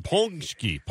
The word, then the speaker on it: paczki: Ponsky.